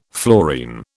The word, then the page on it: fluorine: British English